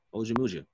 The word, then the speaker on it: Ojemudia: Ojemudia? – Yeah, you got it. Ojemudia.